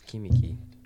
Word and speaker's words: Kamiki: It was Kamaki. No, it was Kimiki. Kimiki. – The, both the Iser's. It's K M M I. Sorry, K A M I K I. Both the Iser's spelled the same.